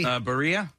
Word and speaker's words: birria: Their specialties include birria. Is that how you say it? – Birria. – Birria.